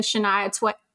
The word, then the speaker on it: Shania Twain: And I remember like having the Shania Twain. I said that right. Okay. It sounds like I was going to say Shania Twain tape.